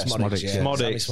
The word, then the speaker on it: Smodics: The only highlight I'll skip to was when, how do you say his name? Is it Smodics? – Yeah, Smodics. Smodics, yeah.